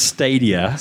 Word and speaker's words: Stadia: So it is pronounced Stadia.